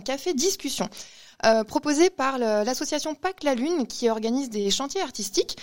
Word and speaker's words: PaQ'la Lune: Sur le site internet de Pâques la Lune, ça s'écrit P-A-Q-L-A-L-U-N-E, tout attaché, .fr.